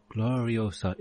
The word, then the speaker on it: Gloriosa: Gloriosa. It rhymes with Furiosa.